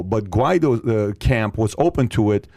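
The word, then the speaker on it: Guaidó: When we were speaking to Juan Guaido's camp, am I saying the name correctly? – Yes. – Juan Guaido's camp, he was going up against Maduro.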